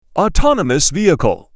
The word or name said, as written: autonomous vehicle